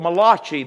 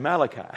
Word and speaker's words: Malachi: I was trying to read that book of Malachi the other day. You mean Malachi. I guess he thought it was an Italian book, Malachi.